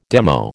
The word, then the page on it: demo: American English